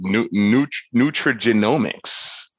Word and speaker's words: nutrigenomics: nutrigenomics.